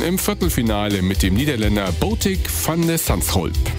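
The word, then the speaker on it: Botic van de Zandschulp: Eieiei, auch gut ausgesprochen. Britta Kern hat es gestern auch schon richtig gut ausgesprochen bekommen. – Dann ja, versuch du auch nochmal. – Botik van de Sandschulp. – Ich hatte den schon mal im Davis Cup.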